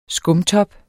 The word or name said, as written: skumtop